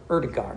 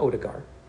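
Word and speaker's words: Ødegaard: Or Erdegar, not Odegaard.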